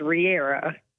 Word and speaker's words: Riera: And my married name is Riera. R-I-E-R-A, but everybody wants to put a V in it because everybody pronounces like Riviera.